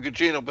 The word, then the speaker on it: Gugino: I will not see it, even though Michael Shannon and the beautiful uh, Carla, how you say her name, Cagino, whatever her name is. – Uh, I'm a pass on that ass. All right. I'm I'm always up for Carla Gugino, but it, it has to be on DVD where I can freeze frame it.